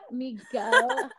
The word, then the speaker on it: Migo: Sounds like you're saying Miko. – Migo, hard G.